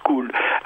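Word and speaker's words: sekoly: mais écoles, ça se disait school, parce que, et ça s'écrit sécoli. Mais ça se prononce school.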